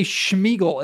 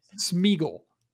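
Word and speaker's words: Sméagol: Why does everybody say Schmeagle? It's not Schmeagle. – Oh, he knew who he is. How do I say it? – Schmeagle. S-M. Schmeagle. Oh. It's not Schmeagle. It's not Whatever. Sean Connery. Smeagle.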